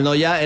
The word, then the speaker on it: Neuer: Manuel Noya era mi abuelo. Yo digo, ese se pronuncia, es que es Neuer, pero se pronuncia casi igual, sí. – Sí, sí, se pronuncia Noya.